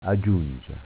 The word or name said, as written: Agiungia